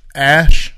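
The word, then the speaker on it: X Æ A-12: XAA-12, which is was pronounced Ash.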